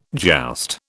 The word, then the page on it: joust: British English